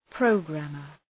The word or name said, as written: programmer